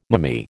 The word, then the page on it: mammee: American English